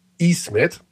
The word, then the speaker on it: Ismet: Das, das I, das große I mit Punkt drauf, also bei Ismet, mhm. wird ausgesprochen wie das deutsche I in Miete. Miete. – Also ein lang I. – Wie langes I. Langes I, Ismet.